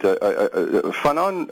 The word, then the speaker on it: Fanon: Tell our listeners briefly the main thesis of Frantz Fanon, and I'm pronouncing the last name correct, am I not? – Yeah, that's correct.